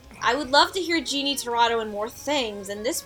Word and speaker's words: Jeannie Tirado: And for a newer voice, and I will probably butcher the last name, Jeannie Tirado. – You actually got it right.